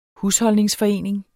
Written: husholdningsforening

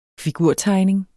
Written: figurtegning

